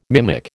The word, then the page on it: mimic: American English